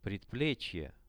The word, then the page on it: predplechie: Russian